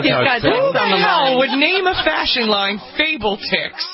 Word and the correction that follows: Fabletics: It's like lettuce, like athletics, and it's fab. Fabletics.